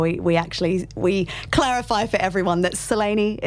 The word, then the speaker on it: Selene: Because it's pronounced differently in Greek, pronounced. – Well, I don't know that I'm going to pronounce it perfectly, but it would be Selene. – Selene. – But with a bit of a UK and Oz twang, we say Selene.